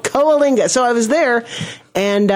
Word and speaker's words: Coalinga: I think they pronounce Kalina, but they're wrong. It's Coalinga.